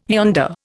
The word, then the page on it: yonder: Indian